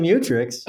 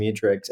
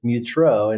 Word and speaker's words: Mutrux: In jury trials, what I'll do is I'll pronounce my name as Tyson Mutrix, and then I'll say for any of you French teachers, it's Mutrux in French.